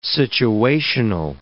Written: situational

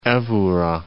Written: evora

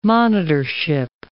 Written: monitorship

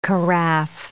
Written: carafe

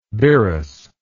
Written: birrus

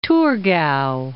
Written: Thurgau

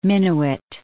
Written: Minnewit

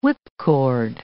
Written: whipcord

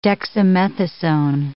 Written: dexamethasone

